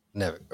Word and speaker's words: NiveK: Nivek. Okay. – Yeah. – I don't want to butcher it. So. – No. Okay. So if you can read that backwards, that's my actual name.